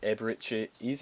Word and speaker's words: Eberechi Eze: There was a few, but I've went for sort of a mess up the pronunciation, but Eberechi Eze, is that how you pronounce his name? – Yep.